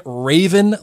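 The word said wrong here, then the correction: Ravn: last week in regards to Rutger Hauer's character. It's not Raven, Blake. It's Raun.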